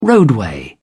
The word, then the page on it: roadway: British English